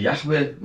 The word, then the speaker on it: Yahweh: Wahrscheinlich war die Aussprache Yahweh, man möge mir das verzeihen,